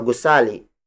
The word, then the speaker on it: gusali: For instance, mataas na gusali. Mataas na gusali. Mataas na gusali. Mataas na gusali is spelled as M-A-T-A N-A-S, space, N-A, space, G-U-S-A-L-I.